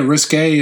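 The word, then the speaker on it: risqué: What's the difference between risky and risque? Pronunciation. – Well, it's spelled different too. – Is it really? – Yeah, risque is RISQUE.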